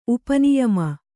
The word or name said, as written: ಉಪನಿಯಮ